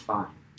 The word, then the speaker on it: Fiennes: I thought it was Fiennes. There's an S at the end. – Yeah, but it's pronounced Fine.